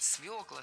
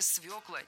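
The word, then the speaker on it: свёкла: Правильный вариант – свекла, свеклу, из свеклы. В этом слове должна быть «ё», и нельзя ее заменять, и переносить ударение на окончание тоже не следует. Только свекла, никакой свеклы нам не надо.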